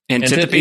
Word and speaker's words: Antiope: And you talk about Antiope. I'm blanking on the exact pronunciation of her name. But Antiope.